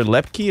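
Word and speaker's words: Luepke: And then you look at Deuce Vaughn and Hunter Lepke. Is that how you pronounce his name? – Yeah.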